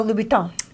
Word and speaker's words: Louboutin: Oh, Louboutin. – Thank you. – Christian Louboutin.